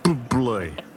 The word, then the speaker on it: Bublé: Michael Bublé. – That's how you pronounce it. That's the proper way.